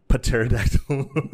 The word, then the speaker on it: pterodactyl: Pterodactyl. – The P is silent.